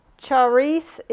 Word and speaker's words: charis: It's the word charis is how you pronounce it. It's C-H-A-R-I-S.